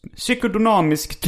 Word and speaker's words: psykodynamisk: KBT och psykodynamisk terapi på kort sikt har likvärdiga resultat. 2. Att de som genomgått KBT sedan ofta får tillbaka sina symptom. Och att 3. Det som gått i psykodoman, psykodona, psykodynamisk tvärtom...